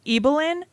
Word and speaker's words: Ibelin: Evelyn. It's spelled Ibelin.